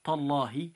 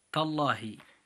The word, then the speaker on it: تالله: أيها الإخوة المسلمون، المثال التالي وهو كلمة تالله، نجد أن اللام الموجودة في تالله موجود قبلها الحرف حرف التاء عند النطق وهو مفتوح، فنقول تالله بتفخيم اللام الموجودة في هذه الكلمة، فننطقها هكذا مرة أخرى تالله.